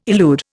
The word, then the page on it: elude: Indian